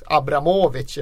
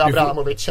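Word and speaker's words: Abramovich: Det är ju tio år sedan vi nu fick, eh, blev upplysta om att Roman Abramovic heter precis så. Det uttalas Abramovic ifall man ska vara korrekt. Men det struntar vi i. – Vi säger ju Abramovic ändå.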